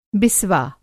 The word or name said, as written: बिस्वा